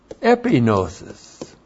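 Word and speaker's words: epignosis: And that is epinosis. E-P-I-G-N long O-S-I-S.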